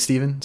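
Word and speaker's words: Stephan: My name's Steven, spelled with a PH and an A like Stefan, but it said Steven.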